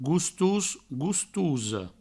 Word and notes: gostos, gostosa: goustous, goustouso; Provençal

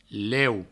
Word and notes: leu: provençal

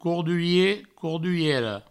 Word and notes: cordurier: corduriera; courdurie; courduriero

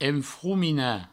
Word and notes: enfrominar: enfroumina

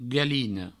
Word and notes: galina: galino

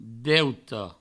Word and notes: deute: Provençal

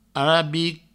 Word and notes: arabic: arabi

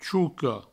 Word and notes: choca: Provençal; chouco

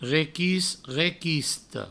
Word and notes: requist, requista: requist, requisto; Provençal

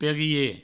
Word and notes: perier: Provençal; perie

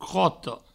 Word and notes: crota: croto

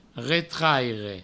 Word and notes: retraire: Provençal

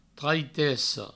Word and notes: traitessa: treitesso

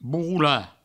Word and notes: borrolar: bourroula

